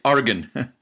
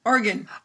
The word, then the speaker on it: Oregon: And there's I think the locals say Oregon. – Oregon. – Oregon. Almost like an organ with an I.